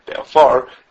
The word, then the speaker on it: be'afar: If the stress is on the ois grinus, then the preceding letter gets a kamatz, and if it's like in our case with a stress is on the end of the word be'afar, then the prefix of the shayrish gets a segel.